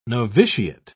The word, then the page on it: novitiate: British English